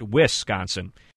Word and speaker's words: Wisconsin: It's a great source for pronunciations of everything Wisconsin, and yes, it is pronounced Wisconsin.